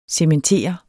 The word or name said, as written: cementere